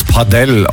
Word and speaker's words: Padel: Padel. Es ist wohl Spanisch. Man soll es Padel aussprechen.